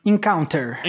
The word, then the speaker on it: Encounter: E agora temos Encounter. É assim que pronuncia? – Encounter.